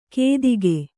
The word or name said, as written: ಕೆತ್ತಿಕೆ